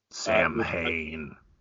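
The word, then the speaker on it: Samhain: Um, it's spelled like a, a, a Danzig's uh, offshoot band. Um, Sam um, Hain. I, I was pronouncing it Sam Hame a little while back, um, which was approximately 13 minutes ago.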